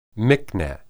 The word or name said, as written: miqneh